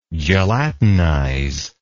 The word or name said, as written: gelatinizer